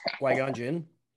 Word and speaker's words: Qui-Gon Jinn: Qui-Gon Jinn.